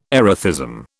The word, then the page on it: erethism: British English